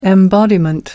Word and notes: embodiment: American English

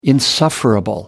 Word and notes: insufferable: American English

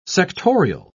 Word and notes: sectorial: British English

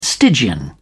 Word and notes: stygian: British English

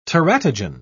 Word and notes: teratogen: British English

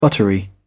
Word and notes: buttery: British English

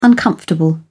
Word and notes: uncomfortable: British English

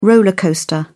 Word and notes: roller coaster: British English

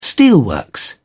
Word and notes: steelworks: British English

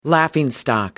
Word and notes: laughingstock: British English

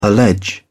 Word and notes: allege: British English

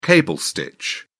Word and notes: cable stitch: British English

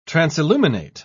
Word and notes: transilluminate: British English

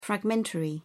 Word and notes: fragmentary: British English

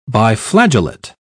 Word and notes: biflagellate: British English

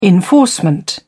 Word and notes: enforcement: British English

